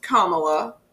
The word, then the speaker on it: Kamala: So Kamala Harris, her name is pronounced Kamala. – Harris is how you pronounce the last name. – Well, everybody can say Harris, but people can't say Kamala for whatever reason.